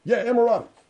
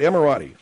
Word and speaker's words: Emirati: Emirati. – All right, Emirati.